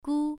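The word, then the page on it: gu: tone 1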